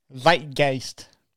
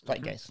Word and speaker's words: Zeitgeist: In 2006, though, George and Chamberlain reconvened to record a new Smashing Pumpkins album, Zeitgeist. Did I say that right? – Yes. – Zeitgeist.